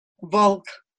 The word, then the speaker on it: Volk: Volk.